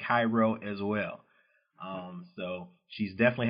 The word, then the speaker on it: Cairo: You know, people pronounce it Cairo, like the Egyptian version. But yeah, right. It's Cairo, the only spot where people pronounce it that way, Southern Illinois.